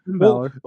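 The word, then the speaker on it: Finn Bálor: Finn Balor.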